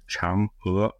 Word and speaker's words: Chang'e: The name of the Chinese Moonlander program is pronounced Chang'e, not Chang'e, because that's the place in Singapore, but it's pronounced Chang'e, like an ER with an upward inflection.